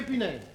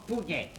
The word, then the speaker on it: Punaise: Je vous salue, monsieur Punaise. – Je suis d'origine espagnole, mon nom se prononce Pugnet.